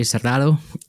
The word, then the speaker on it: Serrato: Okay, we're here with Dr. Margie Serrato, and am I saying that right again? Sorry. – Serrato. – Okay. I got to get that hard T in there.